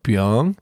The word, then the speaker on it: Byung: I was like, do you mean Byung? He was like, oh, yeah. Oh, that makes more sense. – That makes more sense because that's how you pronounce my name. – My name is spelled B-Y-U-N-G for people that want to know.